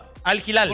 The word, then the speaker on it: Al Hilal: y solamente con decirte que ya, ya sé que traes muchas cosas nuevas desde que le dices al Gilal. Así se pronuncia, ah, ¿no? – Al Hilal.